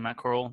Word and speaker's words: Corral: Is that how you say his name, Matt Corral? Yeah, he looked really fucking good.